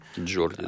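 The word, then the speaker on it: Geordie: Правильно сказал? Джорди, да? – Джорди, да.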